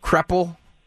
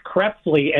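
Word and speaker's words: Krepfle: Was that Charlie Smith, Wilbur Montgomery, Keith Krepfel, or Harold Carmichael? – It's pronounced Keith Krepfley, and it was Charlie Smith.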